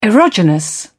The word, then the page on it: erogenous: British English